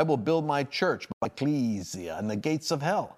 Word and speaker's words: ecclesia: I always tell people, it sounds like a vampire word if it's pronounced right. It's not ecclesia. I've often called it ecclesia. I'm glad to see you, ecclesia, but it's actually ecclesia.